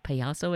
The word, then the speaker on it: Payaso: Payaso, I said it correctly? – Yeah.